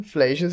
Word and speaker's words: Fleischer: Esta película está dirigida por Ruben Fleischer, o Ruben Fleischer si lo quieren pronunciar en inglés,